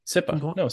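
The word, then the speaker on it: CIPA: As part of E-rate, there is a compliance piece of being SIPA, or I think you pronounced it a different way before we started tonight. Did you say SIPA? – SIPA. SIPA. – Oh, okay. SIPA compliance.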